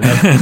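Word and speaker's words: Nevèrÿon: Neverion. That's the pleasure of radio. You get to hear the correct pronunciation.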